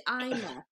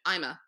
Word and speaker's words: Aima: I'm like, no, like, it's easy. Aima. Like, when they ask me, how do you say your name? Aima. Like, I'm a doctor. Get it? – Yeah.